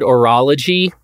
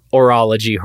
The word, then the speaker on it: horologist: You you like the word orology, and by the way, you're pronouncing the H a lot harder than I would, which you know starts to conjure up some other imagery. Um, but uh, I, I, I, tend to go soft on that H, just oral, her, orology, horology.